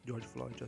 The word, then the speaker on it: George Floyd: George Floyd assim pronuncia, né, que o policial asfixiou ele.